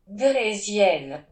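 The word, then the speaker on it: draisienne: اون زمان دوچرخه به اسب شیکبوش، لاف ماشین که در واقع اصطلاح آلمانیشه و دوغزین که اصطلاح فرانسوی اون هست معروف بوده. حالا میتونیم به تلفظ صحیح تر عبارت فرانسویش هم گوش بدیم. درزین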